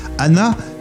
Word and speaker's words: Hana: Anna, ça s'écrit H-A-N-A, ça veut dire fleur en japonais.